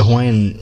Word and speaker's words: Hawaii: And it's funny too, because in the Hawaiian, you know, to Hawaiians, right, it's pronounced Hawaii with a V sound, right?